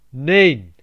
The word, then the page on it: no: /ˈnəʊ/; /ˈnoʊ/